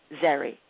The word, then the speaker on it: Zeri: She said, pronounce it like Presley, Zeri.